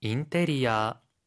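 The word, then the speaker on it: 인테리아 (インテリア): 인테리아라고 할 때는 어가 아니라 아 발음이고 카텐이라고 할 때는 튼이 아니라 텐이라고 발음해 주세요.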